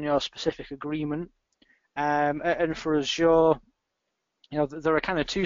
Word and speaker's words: Azure: You've got Office 365, and you've got Azure, or Azure, as Microsoft say we should pronounce it.